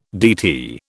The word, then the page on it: DT: British English